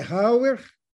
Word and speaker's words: Rauer: Bom, meu nome é Hauer, R-A-U-E-R, do jeito que se pronuncia, o sobrenome é Ribeiro Rodrigues, eu sou de Itiutaba, no Triângulo Mineiro, e sou escritor desde que aprendi as primeiras letras.